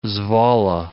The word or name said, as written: Zwolle